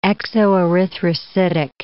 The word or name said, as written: exoerythrocytic